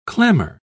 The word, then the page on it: clammer: British English